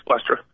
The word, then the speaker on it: Spoelstra: So, maybe Eric, is it Spoelstra? How do you pronounce the Miami Heat coach? – Eric Spoelstra. – Okay, Eric Spoelstra.